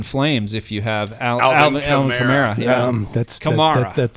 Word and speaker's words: Kamara: It's Alvin Kamara. – I think that's like a European pronunciation of it. So it's Alvin Kamara. – It's like the car. It's a Camaro, but it's a Camara.